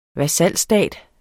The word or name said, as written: VASALSTATER